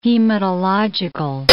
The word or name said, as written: hematological